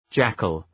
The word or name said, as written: jackal